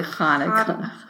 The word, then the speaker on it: Hanukkah: It's really Hanukkah.